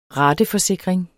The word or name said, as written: rateforsikring